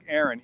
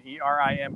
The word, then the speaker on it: Erin: It's pronounced, by the way, Devin, it's not, it looks like Aaron, E R I N, but it's pronounced Erin.